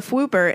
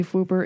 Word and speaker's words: Fwooper: There's a Fwooper in the Magical Menagerie at Diagon Alley, and you can silence it with the magic.